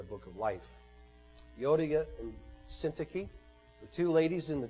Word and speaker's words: Syntyche: affectionately call Yodia and Soon Touchy, although that is not how you pronounce her name. Paul said, I entreat Yodia and I entreat Syntyche to agree in the Lord.